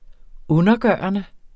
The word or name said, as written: undergørende